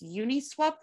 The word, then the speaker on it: Uniswap: In Bitcoin, Ethereum, Maker Compound, and is Uniswap the correct way to pronounce that? – You got it.